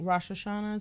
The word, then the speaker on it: Rosh Hashanah: Now, yes, tell us more about Rosh Hashanah.